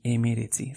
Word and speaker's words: hemeretzi: 9, bederatzi, 19, mrc H-E, M-E, R-E-T-Z-I, emeretzi.